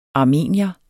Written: armenier

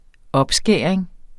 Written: opskæring

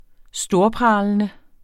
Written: storpralende

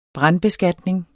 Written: brandbeskatning

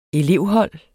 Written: elevhold

